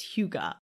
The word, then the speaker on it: Hygge: It's spelled H-Y-G-G-E, okay? So it looks like it's spelled Hygge, but according to Google, it's pronounced Hygge.